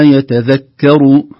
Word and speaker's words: يتذكر: وما يتذكر، الكاف ترقق لأن خوفا من تفخيمها قبل الراء،